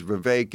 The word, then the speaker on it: Vivek: In his rap, he says, "Vivek, it rhymes with cake."